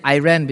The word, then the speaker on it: Iran: Those are places, you know, in the Babylonian area or Iran, or I will pronounce it Iran because I run too much.